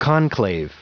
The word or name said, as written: conclave